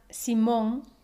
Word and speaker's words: Simón: Simon se pronuncia Simón en español. Pero si quieres guardar el sonido próximo al inglés, puedes pronunciar Simon con el acento en la penúltima Simon.